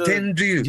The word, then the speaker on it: Tenryu: By the way, English pronunciation is Tenryu, huh? Always. – Yeah, the R-Y-U... – R-Y-U is so hard in English speaking people.